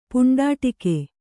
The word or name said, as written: ಪುಂಡಾಟಿಕೆ